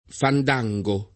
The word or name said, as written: fandango